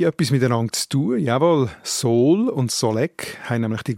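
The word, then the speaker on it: Sool: Normalerweise spricht man es mit langem O aus, Sol, darum schreibt man es an einem Ort auch mit zwei O, zum Beispiel eben beim Sol im Glarnerlang. Das Sol ist ein grosses Gebiet oberhalb von Schwanden,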